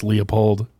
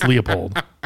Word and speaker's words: Leupold: Stop with this Leupold bullshit. It's Leupold. Just stop. It's Leupold. Just change how you guys pronounce it. Everybody says Leupold, except for people in internet comments. – Right. – Like, it's a Leupold. – Actually. – Yeah, actually, it's Leupold.